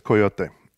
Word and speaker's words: coyote: It's a Spanish word. Like I said earlier, it's coyote. And I think that just like in Texas, you're going to have these mispronunciations of Spanish words, but you're going to kind of see a segue between the Spanish word and then where we're going to say coyote, which is where we're pronouncing that E. But then if you looked at it from an English language standpoint, that E would be silent and make the O a hard O sound.